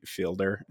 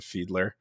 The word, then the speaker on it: Fiedler: I am from Canada, and in Canada we pronounce it uh, field, f- fielder instead of fiedler, which uh, is the mm-hmm. right way to say it.